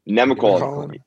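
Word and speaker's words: Nemacolin: Yeah, so you're actually in, I can never say this right, Nemecolon? Am I saying that right? Nemecolon? – Nemecolon. – Yes. – Yes, yes, sir. That's it.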